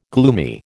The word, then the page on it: gloomy: American English